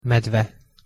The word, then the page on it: medve: Hungarian